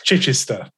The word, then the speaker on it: Chichester: I'm going to mispronounce this. Chichicha, England, which is in the south of England. Correct me on that one. Is that off? – Oh, that's pretty good. It's Chichester. – Chichester.